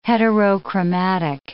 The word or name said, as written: heterochromatic